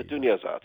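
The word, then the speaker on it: Duniazát: So the Duniazat, if I'm saying that right. – The Duniazat, yes.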